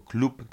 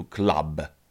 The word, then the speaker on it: club: Może słówko klub wydawać się problematyczne, bo nie mówimy, nie wymawiamy tego jako klub, tylko po prostu club. Club.